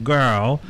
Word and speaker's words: Gurl: So, Girl, it's spelt G-U-R-L, because in the old-fashioned days, the gays would go, hey, girl, like that, girl.